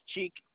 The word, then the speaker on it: Cheick: My first name is Cheek. C-H-E-I-C-K. – And your last name? – Kafando, K-A-F-A-N-D-O.